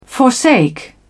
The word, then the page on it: forsake: British English